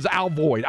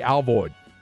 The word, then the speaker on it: Alvord: I'm telling you, the old timers say Alvoid up there, but there's no I in that. So I would split the difference. And pronounce the I like an A, Alvoid.